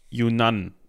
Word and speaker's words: Yunnan: also aus der südchinesischen Provinz Yunnan, so würde ich es jetzt mal aussprechen.